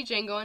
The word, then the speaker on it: Django: And I did see Django Unchained. And I still want to say to Django, but I'm worried that I might get harmed by somebody. – You will, because the D is silent.